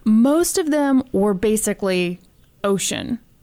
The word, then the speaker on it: Ossian: and I replaced Ossian, which is how it's like spelled and how I would how I want to pronounce it, and now it just says ocean all over this thing.